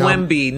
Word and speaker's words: Wemby: Victor Wembley. – Just call him Wemby, not Wembley.